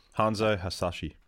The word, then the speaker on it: Hanzo Hasashi: I think the Hanzo Hashi or whatever his name is. – Yeah. – I'm going to butcher it again. – Hanzo Hasashi.